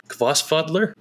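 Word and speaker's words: Hvolsvöllur: And I stayed at a Kvossfadler, which for me, talk about pronunciation, took me a while. It's like H-V-O-L-L, and the H-V is like a K sound, and then the L's is like another K.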